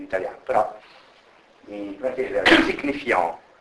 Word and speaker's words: signifiant: Signifiant, la T finale è muta perché fa parte della parola generale deposito, e quindi se non c'è una vocale dietro non va letta in francese, e quindi questa parola in francese si legge signifiant, signifiant. La N nasalizza la A e la T non si legge proprio, quindi signifiant.